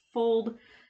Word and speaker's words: Fuld: Fold housing development in Newark, New Jersey. That was a mouthful. – And you said it, you pronounced it perfectly, because it's spelled F-U-L-D, but it's pronounced Fold, like F-O-L-D, so, yeah.